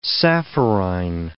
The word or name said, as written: sapphirine